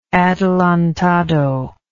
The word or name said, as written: adelantado